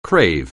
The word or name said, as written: crave